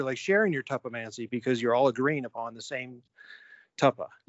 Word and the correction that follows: tulpa: And yeah, so, there's an L in there, tulpa. – Thank you, tulpa. I knew I mispronounced that.